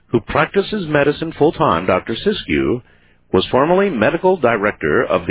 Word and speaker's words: Siskiyou: By the way, Art, it's pronounced Siskiyou. – Siskiyou. – Yeah, that's better.